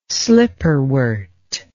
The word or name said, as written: slipperwort